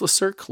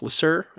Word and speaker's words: Le Cirque: I think it's pronounced Le Cirque, Le Cirque.